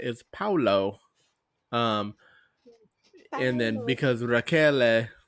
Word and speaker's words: Raquel: So that's how I knew how to say it. Raquel. – Raquel.